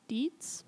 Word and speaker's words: Dietz: So soon after she was in custody, Deanna gets interviewed by psychiatrist Park, I'm going to say it Dietz, Park Dietz, spelled like diets with a Z at the end.